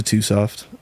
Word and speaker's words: twosoft: So I changed my name to too soft. The two is spelled T-W-O, signifying my second alias. And then soft is whatever.